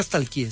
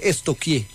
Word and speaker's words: stalkeé: Porque no se dice nunca stalkeé, se dice estoquié.